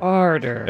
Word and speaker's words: Oerter: It's spelled O-E-R-T-E-R. – Arter.